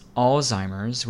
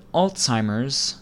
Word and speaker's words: Alzheimer's: You may be tempted to pronounce this Alzheimer's without the T sound, or Alzheimer's like the name Al, but Alzheimer's, like the word all followed by the TZ sound, Alts, is the correct way to pronounce this word, in my opinion.